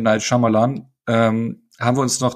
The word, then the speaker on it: Shyamalan: Night Shyamalan. Äh, Shyamalan. Das habe ich gleich sofort falsch gesagt. – Ja. Wo ja das Y stumm ist.